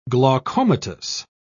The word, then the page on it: glaucomatous: British English